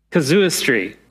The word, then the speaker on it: casuistry: Casustry. Oh man, I really should have looked it up. Casustry. Is it on the screen, Glenn? Casuistry. Thank you, brother.